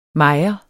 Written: mejer

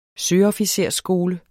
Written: søofficersskole

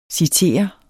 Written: citere

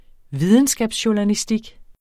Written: videnskabsjournalistik

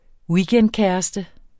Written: weekendkæreste

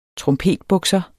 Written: trompetbukser

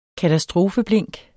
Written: katastrofeblink

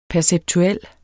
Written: perceptuel

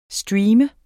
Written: streamer

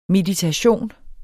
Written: meditation

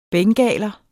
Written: bengaler

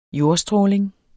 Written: jordstråling

